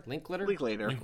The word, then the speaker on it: Linklater: It was written and directed by, I never know how to pronounce this guy's name, Richard Linklater? – Linklater. Is how I've always said it, yeah.